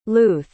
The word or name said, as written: luth